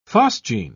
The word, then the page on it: phosgene: British English